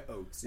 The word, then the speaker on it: Ochs: And it is pronounced Ochs. – Yeah, Ochs. Yeah. – Unless you want to say it with a German accent.